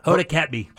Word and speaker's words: Kotb: So now they've announced that it's Hoda, how do you say it? – Hoda Kotb.